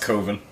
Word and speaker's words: coven: Pronounced coven. – Is it? – Yes.